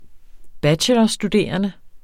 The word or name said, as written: bachelorstuderende